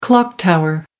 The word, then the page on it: clock tower: American English